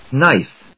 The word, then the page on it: knife: nάɪf